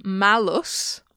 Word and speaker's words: malus: And then with a short A, malus... Malus malum is the adjective bad.